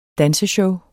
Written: danseshow

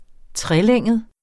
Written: trelænget

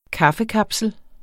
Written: kaffekapsel

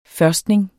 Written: førstning

